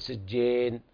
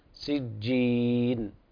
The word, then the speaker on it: سجين: في قوله تعالى أنهم مبعوثون سجين لا تأكل سجين هذه يعني صار فيها إمالة يعني في نطق الحرف صار فيه إمالة لا هذا اللي احنا نركز عليه سجين يعني نرخي الفك إلى أسفل وحتى يخرج الياء من جوف الحلق سجين هكذا.